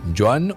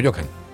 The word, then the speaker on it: Joan: Usted prefiera, Joan o Johan, tiene H intermedia, entonces algunos lo pronuncia como Joan y otros como Johan.